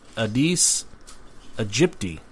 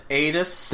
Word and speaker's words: Aedes aegypti: It's Aedes aegypti mosquitoes. – Yeah, Aedes.